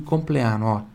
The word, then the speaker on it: compleanno: Pronuncia pure do di aqui, tá? Di compleanno, tá? Di compleanno. E esse A aqui bem forte. O A na língua italiana é sempre bem aberto.